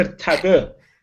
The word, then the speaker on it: Tag Heuer: Tag Heuer. What is he doing? – That good. – Stop with these pronunciations. – Look, man, that's not how it's pronounced. Tagger.